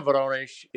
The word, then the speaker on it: Voronezh: So I guess from a geographic standpoint, am I correct that and I'll say it wrong, Voronezh, how did you say the region where you were? – Voronezh. – There you go.